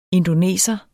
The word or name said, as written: INDONESERNE